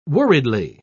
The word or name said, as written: worriedly